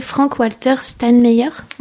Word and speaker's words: Frank-Walter Steinmeier: Frank-Walter Steinmeier.